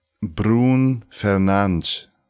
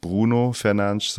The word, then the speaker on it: Bruno Fernandes: Brun Fernandes heißt es richtig. Also nicht Bruno Fernandes, sondern Brun Fernandes. Das O spricht man nicht hinten.